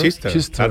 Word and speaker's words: Chichester: Eso sería, se pronunciará Chichester o algo así, ¿no? – Chichester, claro.